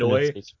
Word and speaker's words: ennui: The French Anhui.